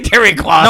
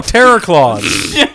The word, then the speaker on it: Terror Claws: You're still saying Terry Claws. – No, Terror Claws. Terror Claws, C-L-A-W-S, like Santa Claus, but with terror.